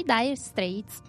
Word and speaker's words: Dire Straits: Sono i Dire Straits. – Ah, Dire bene. Straits. – Se l'ho detto bene, ok. – Sì, sì, sì.